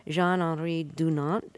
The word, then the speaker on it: Jean-Henri Dunant: It was started in 1863 by Jean-Henri Dunant. Am I pronouncing his name acceptably? – That's right.